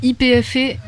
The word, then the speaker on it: YPF: Alors juste une toute petite parenthèse, on dit YPF, si vous cherchez des informations là-dessus, ouais. Ça s'écrit YPF.